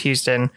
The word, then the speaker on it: Huston: So James Houston is spelled Huston, but pronounced Houston.